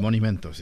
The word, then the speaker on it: monumento: No, pero en español es menumento, ¿no? Monumento, sí. Ahí está. Mon es como dinero. Entonces ahí... monumento.